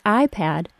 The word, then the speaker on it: iPad: The words iPad and iPod are identical except that iPad is pronounced with a short A and iPod is pronounced with a short O.